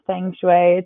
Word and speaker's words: feng shui: Um, feng shui is how I pronounce it. However, if someone says feng shui, it's I, know what they're saying.